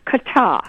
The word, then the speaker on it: Qatar: Your Oxford Collegiate Dictionary, even from 2002, it's pronounced Qatar.